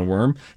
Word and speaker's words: worm: This is a worm. You know, why do we say worm? W-O-R-M, we say worm. W-A-R-M, we say warm. – Mm-hmm. – You would think that W-O-R-M feels like it should be pronounced warm.